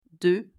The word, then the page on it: 2: French